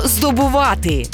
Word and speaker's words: здобувати: Сьогодні передача про слово «здобувати». І наголос в цьому слові ми ставимо на останньому складі.